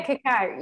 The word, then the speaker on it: cacao: That's Am I saying it right? – So, yeah, cacao.